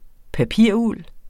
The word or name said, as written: papiruld